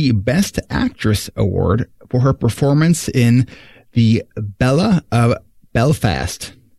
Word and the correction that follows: Belle: The Belle of Belfast.